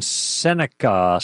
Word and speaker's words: Seneca: All right, to the phones, and number one is Jack in Seneca, South Carolina.